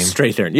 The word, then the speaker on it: Strathairn: Fucking Whistler, David Strathairn. I don't know how to say his actual name. – Strathairn. Yeah, that's right.